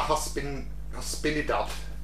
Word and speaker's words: Hispanidad: Día de la Hispanidad. – I love it when we're asking you to pronounce... – Hispanidad? Día de la Hispanidad. – Día de la Hispanidad, yeah.